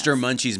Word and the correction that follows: Munch's: Not Munchie's, Munches. Thank you.